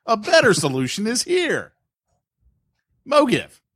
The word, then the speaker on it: MoGive: So not MoGivey? – Not MoGivey. It's MoGive.